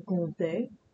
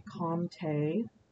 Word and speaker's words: Comte: So the pronunciation is actually Conte. But it is an M sound. So for Americans, you can say comte, like comma, but comte. And it is a te sound, not a T sound at the end. So comte, comte.